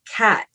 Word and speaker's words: khat: but they have a leaf called Cat, it's spelled K-H-A-T, and they're heavy users of this, and it's a leaf that you chew, and it's a stimulant.